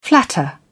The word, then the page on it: flatter: British English